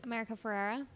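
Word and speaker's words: America Ferrera: Because how do you say her? America Ferrara? Is that how you say her name? – America Ferrara? – Yeah. Is that? – Yeah.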